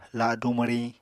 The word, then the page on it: ladu mwriñ: Apatani